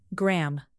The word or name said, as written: gram